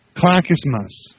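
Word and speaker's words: Clackamas: Clackest mass. Oregon. – Yeah. – How can anybody ever name something simple to pronounce? – What's the name of Blackest? What? – Clackismas. Oh. Clackismas.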